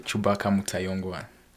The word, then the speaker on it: Cubaka Mutayongwa: Cubaka Mutayongwa.